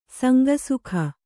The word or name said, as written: ಸಂಕುಸುಕ